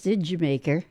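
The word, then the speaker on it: D'yer Mak'er: KLT, the song spelled Dyer Maker, but pronounced Didjamaker.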